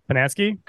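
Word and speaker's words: Posnanski: Posnanksi, correct? – You got it. Absolutely.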